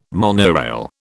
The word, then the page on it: monorail: British English